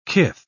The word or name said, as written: kith